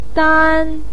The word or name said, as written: dan1